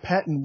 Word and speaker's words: Pettine: Well, first and foremost, I think the biggest concern for the Chicago Bears is the defensive coordinator, Mike Pettine. Did I pronounce that correctly? – You did, yes.